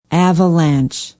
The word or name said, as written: avalanche